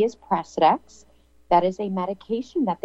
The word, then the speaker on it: Precedex: Precedex.